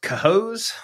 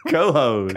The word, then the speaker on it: Cohoes: Is pronounced Cohoes. – Cohoes.